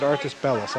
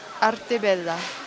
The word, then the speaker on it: Arte Bella: I said Artis Bellas. I like to make fun of those things, but I shouldn't. – Arte Bella.